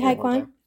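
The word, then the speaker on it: Ke Huy Quan: Ki Hai Kwan.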